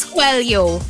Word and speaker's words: Coelho: A lot of people pronounce it Coelho, diba? Coelho. Yeah. But it's Coelho.